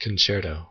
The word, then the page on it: concerto: American English